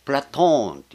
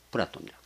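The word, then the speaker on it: プ ラ ト ーン (Πλάτων): プラトーンっていうのが本来なんです。プラトンじゃなくて、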